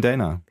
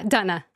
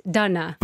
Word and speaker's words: Dana: Moin Dana. – Wait, sorry, Dana. – Tatsächlich? – Ja, Dana. – Warum? – Also meine Vorfahren kommen aus Tschechien. Und so mein Name ist D-A-N-A buchstabiert, aber es ist Dana.